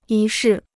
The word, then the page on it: 仪式: yí shì